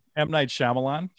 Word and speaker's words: Shyamalan: How do you say that guy's name? – M. Night Shyamalan. – Shyamalan, yeah.